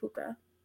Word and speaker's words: pooka: And it's literally spelled like P-O-O-K-A, puka.